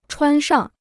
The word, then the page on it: 穿上: chuān shang